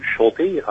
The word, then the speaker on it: Schulte: My name is Bruce Schulte. Last name is spelled S-C-H-U-L-T-E.